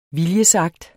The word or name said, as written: viljesakt